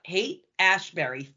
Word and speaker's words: Haight-Ashbury: He starts attending the, and am I saying this right? Haight-Ashbury, yeah. – Yep, Haight-Ashbury, free medical clinic.